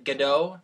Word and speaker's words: Gadot: Gal Gadot, am I saying that right? Gadot? – Yeah.